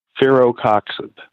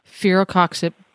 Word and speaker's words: Firocoxib: Firocoxib. – Firocoxib.